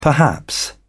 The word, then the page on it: perhaps: British English